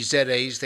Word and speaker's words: ZEDE: So tell us, if you will, somewhat briefly, the story of the, as you call them, the ZAs, Z-E-D-E, ZAs, they're pronounced, the ZAs in Honduras.